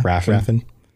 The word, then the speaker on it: Raffen: R-A-F-F-E-N. How would Raffin. You pronounce that? – Yeah. – Raffin.